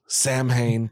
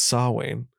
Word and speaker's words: Samhain: Samhain, by the way, it's pronounced Sawain.